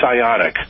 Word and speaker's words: Ioniq: It's IONIQ. And what's the correct pronunciation of that vehicle? – Oh, I hope it's Ionic, because that's what I've been saying.